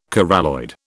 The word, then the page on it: coralloid: British English